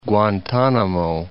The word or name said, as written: Guantanamo